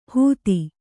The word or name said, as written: ಹೂತಿ